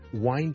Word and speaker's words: whine: 例如愛爾蘭嘅英文呢好多時候呢都會聽到 wine, wine, 同埋 whine 發音是不同的，聽住第一個是 wine wine wine wine wine，第二個是 whine whine whine whine whine，聽落去你嘅感覺咧，去當佢好似 h 音之前跟住先加個 wine 咁，whine and wine，咁就唔係錯唔錯嘅問題，而係唔同地方口音。